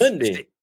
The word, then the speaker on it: Hyundai: It's Hyundai. See, like this. It's just like this. Scoremaster says, Hyundai, I shrunk the kids. – Hold on. – That's how you say it.